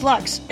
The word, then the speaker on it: Lux: My name is Lux. It rhymes with trucks, and also ducks, and also flux, and also...